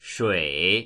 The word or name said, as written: shui3